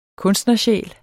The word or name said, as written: kunstnersjæl